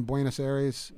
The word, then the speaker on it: Buenos Aires: Um, you know, we we picked out the cover, which is a shot of us in Buenos Aires.